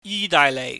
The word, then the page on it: yidaaihleih: Cantonese